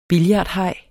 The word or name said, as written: billardhaj